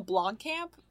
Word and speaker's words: Blomkamp: Neil Blomkamp? Blomkamp? Is that how you say his name? – Yeah.